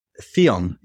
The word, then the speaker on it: Theone: Theone. Yeah, there you're saying that right. You can say as you want to. It's a play on words. The one put together, Theone.